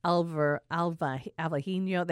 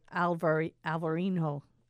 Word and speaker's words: Alvarinho: And the only one that most people, I think, would have any idea is Albarino, which is really Alvarinho. They pronounce it different, but it's the Albarino in Spain. There's a V and an H. In the same Alvarinho in Portugal.